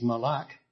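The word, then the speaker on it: mal'ak: Well, the Hebrew word for angel is malak. I'll spell it M-A-L, little apostrophe comma, A-K, malak.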